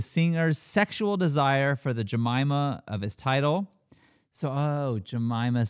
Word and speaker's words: Jemima: Jemima and Jemima, same spelling. Duh.